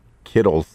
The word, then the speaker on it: Kittle: Well, it rhymes with Skittles.